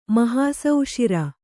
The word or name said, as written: ಮಹಾಸೌಷಿರ